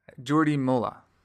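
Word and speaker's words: Jordi Mollà: Do you know how to pronounce his name? – His name is Jordi Mola.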